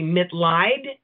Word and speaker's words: Mitleid: The last name is pronounced Corbie Mitleid, okay.